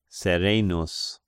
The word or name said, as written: Serenus